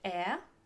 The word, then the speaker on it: heir: Deuxième mot, HEIR, H-E-I-R. Attention, c'est pas les cheveux. Les cheveux, le H se prononce bien. Hair. Là, je parle de HEIR, euh, l'héritier, H-E-I-R.